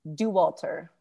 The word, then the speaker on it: Dauwalter: what is the correct pronunciation of your last name? – Dauwalter. – Dauwalter, not Dauwalter. – Nope. – And it is, what is that, German? – It is German. Yeah.